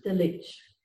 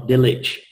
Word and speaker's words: Delitzsch: Down to my lousy German pronunciation, I will probably get this wrong, but there is a commentary written by Kyle and Delitzsch. – There you are, Delitzsch.